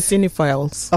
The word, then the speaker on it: cinephiles: Oh yeah, you pronounce it all as cinephiles.